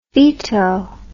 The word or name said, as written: feto